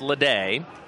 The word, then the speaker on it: Lada: 1-1 to Lade. Again, spelled L-A-D-A, but pronounced Lade.